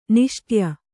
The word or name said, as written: ನಿಶ್ಶಾಠ್ಯ